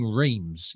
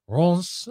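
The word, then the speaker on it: Reims: At least I'm not saying reams. You know, I could be saying reams or rems. You know, I'm giving it the best French pronunciation I possibly can. Rons, you know, that's hoping is as close to the real thing as I can get it.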